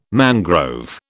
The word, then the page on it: mangrove: British English